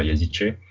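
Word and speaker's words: Yazıcı: notamment Yazizi. Euh, je crois que c'est pas comme ça qu'on prononce, mais. – C'est comme ça, Yazice.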